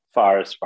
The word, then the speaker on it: Fares: Am I pronouncing that? I believe I'm pronouncing. – Yeah. Fares, yeah. You might not have the native intonation, but I think you got the pronunciation correct.